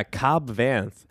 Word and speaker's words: Cobb Vanth: Cod Vanth, is that how you say his name? – Yeah, Cobb Vanth.